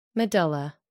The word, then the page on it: medulla: American English